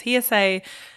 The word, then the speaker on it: Hearsej: Yes, it's spelled H-E-A-R-S-E-J, because my own name is S-E-J-A, Saya. – Sure. – So it's Hearsay.